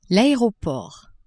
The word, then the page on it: aeroport: French